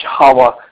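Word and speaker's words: Hawah: If anybody just wants to email me personally, my first name is spelled H-A-W-A-H. H in the front, H at the back, A-W-A-H, Hala. And just make that at One Common Unity, all one word, O-N-E, common, U-N-I-T-Y .org, and that'll come right to my inbox.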